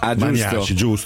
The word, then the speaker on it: Maniaci: Per introdurre Pino Maniaci, buongiorno. – Buongiorno a voi, Pino Maniaci. – Buongiorno. – È una questione di accento. – Maniaci, giusto, scusa.